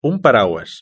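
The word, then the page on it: un paraguas: European Spanish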